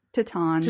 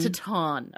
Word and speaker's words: Titane: Or is it Titane? How do you say it? – Titane. – Titane. Okay, sorry. – But with a French accent that none of us can do.